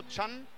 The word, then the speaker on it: Chan: Ja, Huang, der Koreaner mit der 20, der heißt Chen, also Chan. – Chan. – Chan wird ausgesprochen, das wollen wir ganz genauso machen und wir sehen dieses 1887 Hamburger Sportverein-Ding, das fällt noch nicht so ganz runter.